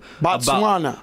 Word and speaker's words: Botswana: Yeah, the article says every person alive today descended from a woman who lived in modern day, I don't even know how to say this, Botswana. – Botswana. – About 200,000 years ago, a new study finds.